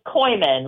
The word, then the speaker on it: Coyman: Yeah, so it's Koyman, like Koyman. – C-O-Y-M-A-N, and it's Anna, like Anna Banana.